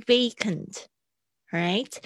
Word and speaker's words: vacant: 这边我特别想要讲一下 oxygen mask 跟 turbulence，因为是我在改作业的时候发现大家比较容易念错。还有 vacant，vacant 很多同学会念成 vacant，不行。 v v vacant，right？